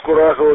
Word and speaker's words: Курахово: Правильно, город Курахово, ударение правильно делаешь, совершенно верно.